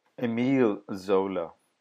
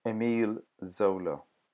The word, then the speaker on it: Emile Zola: Emile Zola. Emile Zola. Emile Zola? That's me correcting the pronunciation of the author that you will hear in this podcast.